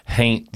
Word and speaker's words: haint: it's called Haunt Blue. – Haint Blue. I'm sorry. – Haint, but you have to say it like haint.